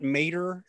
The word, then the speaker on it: Maderer: You pretty much can look for either Scott Maderer, and my last name is spelled M-A-D-E-R-E-R, or Inspired Stewardship.